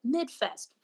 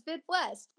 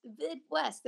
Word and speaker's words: VidWest: And there people were like MidFest, VidWest Fest, music video, you know, they didn't know how to say it, but they knew something, the MidWest Fest, you know. And we were like, yeah, it's VidWest, VidWest.